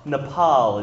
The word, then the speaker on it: Nepal: Nepal is actually how you say it.